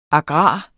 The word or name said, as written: agrar